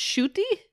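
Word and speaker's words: Ncuti: I'm still caught on the pronunciation. It's Shooty. – Shooty, oh, yeah, right. S-H-O-O-T-Y is how it's pronounced.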